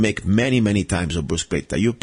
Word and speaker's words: bruschetta: Probably in English you say bruschetta, okay? I know that when I go to America, you say bruschetta. I want to tell you exactly what how to say it in Italian. It's called bruschetta. And let me spell it for you. B-R-U-S-C-H-E-T-T-A, okay?